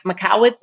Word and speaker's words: Michalowicz: Michalowicz, I believe is how you pronounce it.